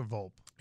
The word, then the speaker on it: Volpe: Is it Volpe or Volpe? – It's Volpe. I always butcher it, but yeah, it is Volpe.